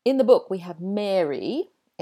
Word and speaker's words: Mary: So to me, the girl's name, Mary, that rhymes with scary or hairy. He's got a lot of hair. He's very hairy. Or airy. That room is airy. Oh, that's a really weird sentence, isn't it? Whereas the boy's name, Gary, G-A-R-Y, that rhymes with Harry, as in Harry Potter, or Prince Harry. And so Mary, the girl's name, has an air vowel sound, and Gary, the boy's name, has an ah sound.